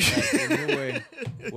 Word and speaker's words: Inoue: Inoue. Is that how you pronounce it? – Oh, Inoue for sure.